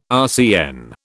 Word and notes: RCN: British English

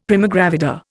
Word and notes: primigravida: Indian

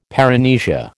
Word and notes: paramnesia: American English

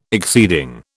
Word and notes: exceeding: British English

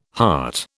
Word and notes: hart: British English

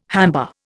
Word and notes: hamba: Indian

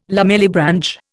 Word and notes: lamellibranch: Indian

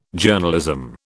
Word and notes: Journalism: British English

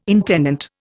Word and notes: intendant: Indian

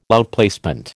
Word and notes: outplacement: American English

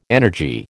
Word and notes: energy: American English

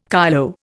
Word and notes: kyloe: Indian

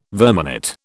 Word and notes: verminate: British English

